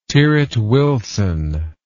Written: tyrwhitt wilson

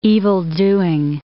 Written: evildoing

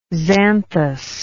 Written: xanthous

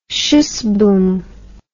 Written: schuss boom

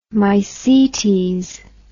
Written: mycetes

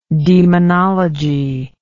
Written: daemonology

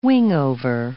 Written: wingover

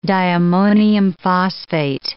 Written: diammonium phosphate

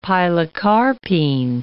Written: pilocarpine

